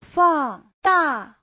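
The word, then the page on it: fangda: fang4da4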